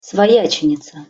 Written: svojacenica

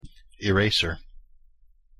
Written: eraser

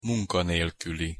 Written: munkanelkuli

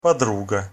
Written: podruga

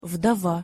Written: vdova

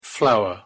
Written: flower

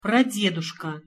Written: pradeduska

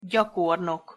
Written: gyakornok

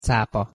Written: capa

